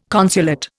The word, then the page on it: consulate: Indian